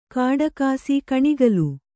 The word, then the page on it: ಕಾಡಕಾಸಿ ಕಣಿಗಲು: kāḍakāsi kaṇigalu; Kannada